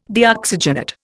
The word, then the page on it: Deoxygenate: Indian